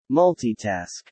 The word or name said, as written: multitask